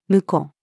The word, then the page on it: 婿: female